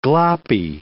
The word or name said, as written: gloppy